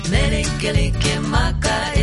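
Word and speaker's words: Mele Kalikimaka: It is the Hawaiian pronunciation of Merry Christmas. It's actually not a different language, just a different pronunciation of Merry Christmas. Meli Kaliki Maka.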